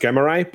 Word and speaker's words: Gremorai: Although, just as a quick thing, as again with the language of my people as it has evolved over the years, Greymare is not incorrect. – Oh, either. Okay. – So you could call it Grey. It's originally pronounced Gremorai, but what is also commonly acknowledged from people who study the language is that both are completely acceptable.